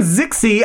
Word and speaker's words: Zixi: Queen Zixi of Ix. I said it... Pronounced it wrong the first time.